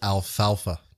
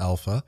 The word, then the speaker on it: alfalfa: It's called alfalfa, not alpha.